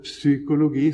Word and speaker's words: psykologi: Også rent psykologisk sett, som vi sier, ordet psykologi, som altså egentlig skal staves med PS, også uttales med PS, psykologi.